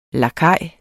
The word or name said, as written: lakaj